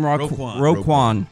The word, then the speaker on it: Roquan: This is linebacker for the Ravens, how do you say his first name? – Roquan.